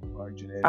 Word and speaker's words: Marty Jannetty: I'm gonna Marty Jannetty your ass. I'm just trying to get. I'm so, Shawn Michaels. Yes, I am. – No, you just said Marty Jannetty.